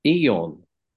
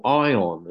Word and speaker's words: Aion: When I was young, someone gave me Eon, or Ion as it's pronounced, A-I-O-N, and that was far too too advanced for me.